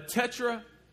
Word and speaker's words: tetragrammaton: So when they would write out God, they would use what's called a tetragrammaton. Did I say that right? A tetragrammaton, actually. Okay, there we go.